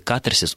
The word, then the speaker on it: катарсис: В традициях русской культуры, насколько я могу представлять себе, как минимум два века, когда, собственно, стали появляться первые орфологические словари, словари трудностей, в слове «катарсис» ударение должно ставиться на первый слог, и большинство орфоэпических словарей именно только как одни единственный вариант и фиксирует вот эту вот форму.